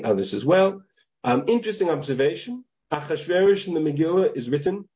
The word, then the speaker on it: Ahasuerus: That's the way it's written in the Megillah. We pronounce it Ahasuerus, but the Ketiv Kri there is Ahasrash.